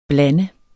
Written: blande

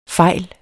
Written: fejl

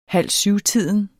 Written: halvsyvtiden

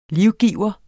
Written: livgiver

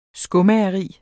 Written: skomageri

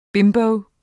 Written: bimbo

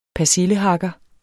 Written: persillehakker

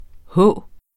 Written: h.

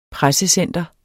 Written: pressecenter

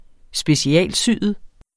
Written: specialsyet

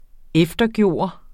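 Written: EFTERGØRES

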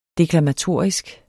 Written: deklamatorisk

